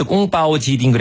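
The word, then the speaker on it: 꽁빠오 지딩 (宫保鸡丁): 그 중국어 발음으로 이제 꽁빠오 지딩 그래가지고 이제 닭고기를 이제 약간 강정인데 튀기진 않았어요.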